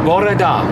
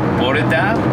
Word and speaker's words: bore da: How to say good morning is how they say it is bore da. – Bore da? – Bore da. Exactly. That is good morning in Welsh.